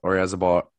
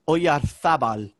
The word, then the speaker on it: Oyarzabal: But I think the Z is kind of like a thud, like Oyarzabal is some way I've heard, like, Oyarzabal.